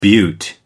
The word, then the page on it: beaut: American English